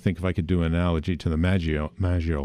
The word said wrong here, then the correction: Maginot: Yeah, like Maginot that's, line. – Maginot line. I didn't pronounce it right. I pronounce it almost like Italian or something, right?